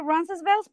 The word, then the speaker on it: Roncesvalles: Yo lo pronuncio Roncesvalles porque así es lo como la gente me entiende.